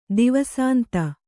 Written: ದಿವಸಾಂತ

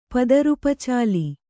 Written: ಪದರುಪಚಾಲಿ

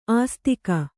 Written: ಆಸ್ತಿಕ

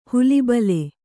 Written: ಹುಲಿಬಲೆ

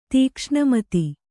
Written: ತೀಕ್ಷ್ಣಮತಿ

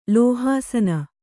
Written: ಲೋಹಾಸನ

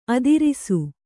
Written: ಆದರ್ಶ

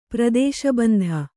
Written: ಪ್ರದೇಶಬಂಧ

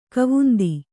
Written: ಖಾವಂದ

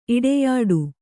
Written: ಇಡೆಯಾಡು

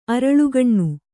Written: ಅರಳುಗಣ್ಣು

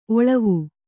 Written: ಒಳ್ವು

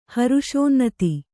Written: ಹರುಷೋನ್ನತಿ

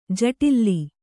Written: ಜಟಿಲ

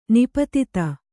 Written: ನಿಪತಿತ

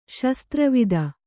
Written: ಶಸ್ತ್ರವಿದ

